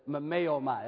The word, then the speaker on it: mimeomai: The word in the original language, phonetically, it says mimeomai. That's the way it sounds. Mimeomai. M-I-M-E-O-M-A-I, okay?